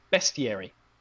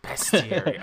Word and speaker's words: bestiary: We've gotten feedback, and that's been the main piece of feedback we've gotten, is that it's pronounced bestiary. – I know. I think, you know, Boy Bill just thought, oh, well, there are a bunch of beasts. It must be bestiary.